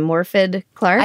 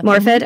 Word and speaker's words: Morfydd: You see Maud, the main character, you see, and you'll have to help me with the pronunciation of this brilliant actress's name, Morfydd Clark. – I think Is it's that? Morfydd, yeah. Morfid, mm-hmm.